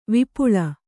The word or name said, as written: ವಿಪುಳ